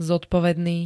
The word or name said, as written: zodpovedný